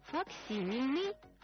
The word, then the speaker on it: факсимильный: А я еще раз об ударении. Факсимильный, факсимиле.